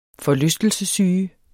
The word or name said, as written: forlystelsessyge